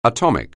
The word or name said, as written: atomic